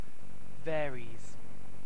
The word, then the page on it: varies: British English